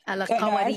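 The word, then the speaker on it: Al-Qarawiyyin: It's a very difficult word to say if you Al-Karawin. Yeah, there's that emphasis on the Y.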